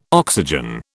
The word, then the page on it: oxygen: British English